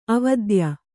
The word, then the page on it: ಅವಿದ್ಯೆ: avidye